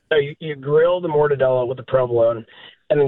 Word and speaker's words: mortadella: So it's 3 stacks of mortadella. Am I pronouncing that right? – Mortadella, that's right.